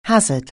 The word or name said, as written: hazard